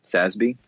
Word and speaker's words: SASB: And the key one to respond to this question is the Sustainability Accounting Standards Board, SASB. – Yeah. – Uh, it's no no accident that it rhymes with FASB.